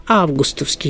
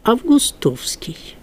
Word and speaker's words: августовский: Только августовский есть в толковых словарях, во всех, но в орфоэпических словарях, тех, которые специально на произношение направлены, да, или, как сейчас говорят, заточены, вот они начинают такую толерантность проявлять и говорят, и допустимо августовский. Понимаете, это вот уступка обычаю произносительному, да? Но вот толковые словари, еще раз повторяю, даже последние пишут августовский и наречие по-августовски.